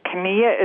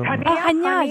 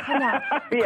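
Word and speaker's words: Chania: I think, is how you pronounce it, Kania? Is that where I should be looking at? – I don't know. – Hania, you mean Hania? – Yeah. – Hania.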